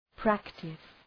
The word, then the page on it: practice: ‘præktıs